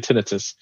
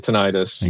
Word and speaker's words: tinnitus: Doctors right. always say tinnitus. Civilians tend to say tinnitus. – Exactly. – And doctors have it's like a guild thing. They have their own pronunciations, angina instead of angina.